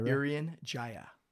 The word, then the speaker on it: Irian Jaya: Irian Jaya.